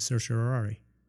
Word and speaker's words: certiorari: Differences between the certi or the certsi, and between rari or rari, and more. And if you're wondering, Justice Alito and Justice Stevens agree with me by pronouncing it certiorari.